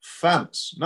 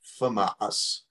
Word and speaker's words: FAMAS: It's FAMAS, not Fumas.